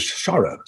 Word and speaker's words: chara: is chara, C-H-A-R-A, chara, I presume it's pronounced.